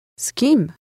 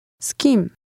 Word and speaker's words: z kim: Z kim? Kim? Z kim? Z kim? – Did you hear the S sound at the beginning?